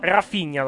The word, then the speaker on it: Raphinha: No, io ce l'ho con l'H qui, R-A-P-H-I-N-H-A. La pronuncia dovrebbe essere Rapinha. – No, Rafinha dovrebbe essere la pronuncia, se quella...